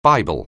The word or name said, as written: Bible